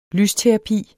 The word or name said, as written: LYSTERAPI